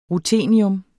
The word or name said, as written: ruthenium